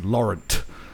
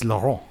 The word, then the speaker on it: Laurent: And like that's how like English my dad tries to be, where he's always insists that my name is Laurent. But it's Laurent.